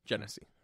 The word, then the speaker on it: Genesee: But it is Genesee.